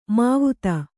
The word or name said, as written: ಮಾವ್ತ